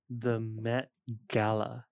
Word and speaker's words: Met Gala: The Met Gala. Am I saying that correctly? – Yeah, you did.